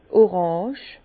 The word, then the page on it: d' Orange: Bas Rhin; Herrlisheim